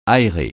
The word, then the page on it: aérer: francais